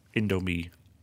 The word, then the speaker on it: Indomie: including some shorts made out of Indomie packaging.